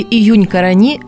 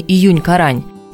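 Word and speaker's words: Июнь-Корань: На сопке июнь-карани жарко и ветрено. Правильно говорить именно июнь-карани, а не июнь-карань, как, впрочем, чаще всего и называют это место – еще более привычное название – Волочаевская сопка.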